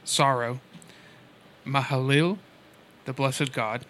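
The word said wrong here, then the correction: Mahalalel: Mahalalil. You missed a syllable in there.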